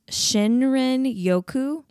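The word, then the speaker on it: Shinrin Yoku (森林浴): but it looks like it is pronounced Shinrin Yoku, which is a form of ecotherapy.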